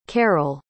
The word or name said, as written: carole